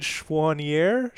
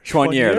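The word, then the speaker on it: Choinière: We've been pronouncing the name of Forge's eventual goal-scoring, you know, series-deciding winner incorrectly. So we've been told that it's David Schwanier. – Schwanier, that's correct. Yeah.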